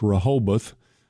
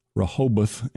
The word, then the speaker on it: Rehoboth: It's Rehoboth. It's R-O-H-O-B-O-T-H, Rehoboth, and it is a wonderful place.